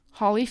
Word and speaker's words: Holly: So I'm just going to quickly mention my Twitter handle is at Holly Felmuth, my name. That's spelled H-O-L-L-Y, F as in Frank, E-L-L-M-E-T-H, all one word.